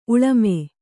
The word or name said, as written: ಉಳ್ಮೆ